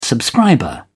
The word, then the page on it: subscriber: British English